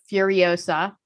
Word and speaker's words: Furiosa: Charlize Theron turns in my favorite female hero of all time as Furiosa. Am I saying that right? – You are.